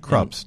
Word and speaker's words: Krups: Krups, not craps, Wendy. It's Krups. – Oh, yeah. – You're saying it wrong.